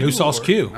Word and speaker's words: No Sauce Que: Is it just Q? – No sauce Q. – Oh, okay. – Yeah, it's spelled Q-U-E.